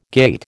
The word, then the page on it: gait: American English